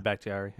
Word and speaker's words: Bakhtiari: Finally, they are getting back David Bakhtiari. – Yeah, that's huge. – Whatever the hell his name is. – You said it right. David Bakhtiari, yeah.